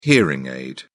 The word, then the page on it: hearing aid: British English